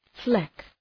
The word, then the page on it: fleck: {flek}